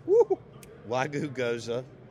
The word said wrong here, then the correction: gyoza: Did I say that right? – Gyoza. – Gyoza. – It's like Asian dumplings. – I just pronounce it like if you're from Baden. – Like you coached Charles Cross in high school. – Gyoza? – Yeah, nailed it.